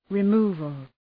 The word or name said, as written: removal